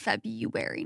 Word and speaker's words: February: And it's February now. No, I've always said February my entire life because of the R.